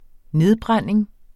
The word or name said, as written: nedbrænding